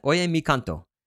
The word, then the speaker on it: Oye mi canto: La Bamba is an obvious example, though Gloria Estefan pulled off the feat with the ballad Oye mi canto? Is that how you pronounce that? Oye mi canto. – Yeah.